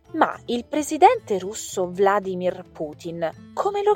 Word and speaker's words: Putin: Ebbene, c'è anche un'altra parola che si pronuncia praticamente allo stesso modo e si scrive in maniera quasi uguale, ed è Putin, P-U-T-I-N, quindi alla fine finisce con tin, no, Palestine, quindi senza A.